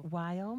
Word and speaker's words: Weill: Weill mm-hmm.